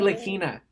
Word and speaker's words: Ntilikina: Nitalikinka. How do you say that name? – Ntilikina, Noah.